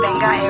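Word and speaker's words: Vengaboys: It's spelt with a V, though, you know. Benga. It's supposed to be Benga, apparently.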